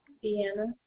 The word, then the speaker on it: Deanna: Deanna.